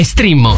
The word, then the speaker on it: Streammo: Vai su streammo.it. Si scrive Streammo o scarica l'app.